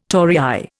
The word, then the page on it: torii: Indian